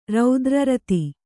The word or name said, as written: ರೌದ್ರರತಿ